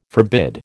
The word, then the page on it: forbid: American English